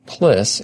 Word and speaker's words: PLSS: PLSS, pronounced PLIS, it stands for Personal Life Support System.